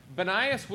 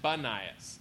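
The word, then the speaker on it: Banias: Bannias was called Caesarea Philippi, and before it was called Caesarea Philippi, it was called Panias.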